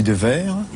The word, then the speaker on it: Dewaere: Alors moi, je dis Devers. – Mais ça s'écrit avec un V.